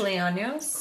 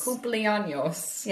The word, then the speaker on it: cumpleaños: Cumpleaños. – Cumpleaños.